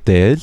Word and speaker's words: deel: So our traditional costume is called dil. So in English, it's spelled D-E-E-L, so D-E-E-L, and it's kind of like a robe.